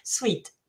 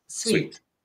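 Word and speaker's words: suite: Así que tenemos esta primera que no es sencilla, porque nos puede salir un poco como el dulce, ¿no? Sweet, sweet. Pero ¿cómo pronunciarías tú esta? – Ahí tengo dificultad, porque a ser francesa me sale la palabra en francés, pero lo voy a intentar. Decimos sweet, sweet.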